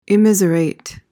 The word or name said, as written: immiserate